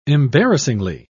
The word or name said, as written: embarrassingly